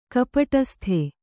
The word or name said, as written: ಕಪಟಸ್ಥ